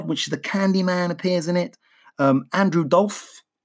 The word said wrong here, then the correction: Divoff: is the, I think I'm saying that right. – No. – Andrew Dolph. – Oh yeah, do you remember? Because we were trying to pronounce his name and I was like, oh, yeah. Divoff. – But thanks. Yeah, that's right.